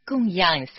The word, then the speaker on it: 供养: 附带说明一下供养三宝的样这个字的发音，自古我们中国人对上是讲供养第四声，对下是讲教养养育，养是读作第三声，这里是供养三宝读作第四声样。